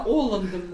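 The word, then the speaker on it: Олланд: Оланд принял патриарха Кирилла в Париже. Принял. – Принял. – Оланд, да, Оландом называется. – Да, пусть Оланд, какая разница. – Французский, у них же все ударения на последний слог.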